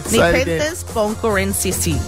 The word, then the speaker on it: Nepenthes bonkerensis: That is not how you pronounce it. – It actually is. Nepenthes bonkerensis.